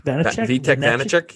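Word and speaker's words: Vítek Vaněček: I think that goaltender from Washington, Vitek, gonna butcher his last name, Vanacek? – Vitek Vanacek, yeah.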